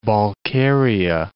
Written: Balkaria

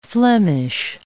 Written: Flemish

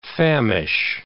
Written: famish

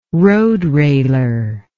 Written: roadrailer

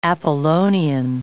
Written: Apollonian

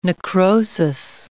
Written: necrosis

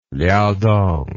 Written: liaodong

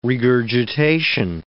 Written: regurgitation

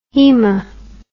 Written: haema

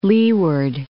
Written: Leeward Islands

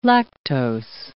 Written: lactose

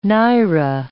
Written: naira

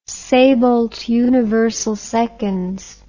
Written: saybolt universal seconds

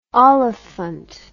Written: olifant

